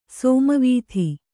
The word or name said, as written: ಸಾಮವೇದ